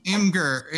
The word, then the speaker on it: Imgur: Oh, Im- Imgur. Im- oh, that's how it's pronounced.